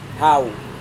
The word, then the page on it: hau: Serewen